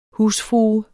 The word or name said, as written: husfrue